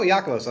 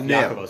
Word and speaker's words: Yakovos: He said, oh, Yakovos." I was like, okay, so note self, Yakovos. Yakobos, Yakobos. He's yeah. Greek, so it's spelled differently than you would expect.